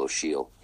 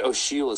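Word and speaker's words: Ó Siadhail: It's Michal O'Shiel. And O'Shiel is spelled O-S-I-A-D-H. – You'll have to A-I-L, of course.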